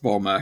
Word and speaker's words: Bulma: It's kind of a weird Japanese pronunciation of the word bloomers, but yes. It's actually a family thing with Bulma.